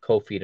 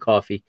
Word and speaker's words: Kofi: Looks like Kofi to me, but it's they're pronouncing it Coffee.